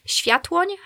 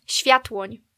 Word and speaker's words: Sviathłoń: Es würde eigentlich Sviathłoń heißen. – Nochmal? – Sviathłoń. – Okay. – Ja, ist ein bisschen schwierig zu aussprechen. Aber in der deutschen Schreibweise ist einfach Sviathlon.